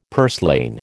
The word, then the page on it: purslane: American English